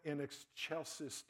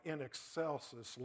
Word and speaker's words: in excelsis: And by the way, something I only learned a few years ago, it's spelled like in excelsis, like it's Excel spreadsheet. It's actually pronounced in excelsis, like a CH.